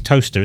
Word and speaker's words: Towcester: The town is Toaster, isn't it? – Yeah. – But it's spelled Towcester. – Yeah. – But it's... – Don't fall into the trap of Americans going Gloucester and what have you.